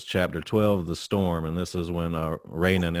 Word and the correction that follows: Rannan: if I'm mispronouncing it, forgive me. – It's actually Rannan. – Rannan, gotcha. This when he's riding with the shattered shields and they're rowing against the demon winds.